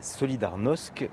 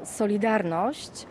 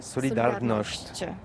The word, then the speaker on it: Solidarność: Alors solidarité, en français on dit solidarnosc, mais ici à Gdansk on ne prononce pas solidarnosc. – On prononce solidarnosc. Par contre, Solidarność.